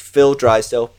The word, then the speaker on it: Phil Drysdale: So I'm just Phil Drysdale, P H I L D R Y S D A L E.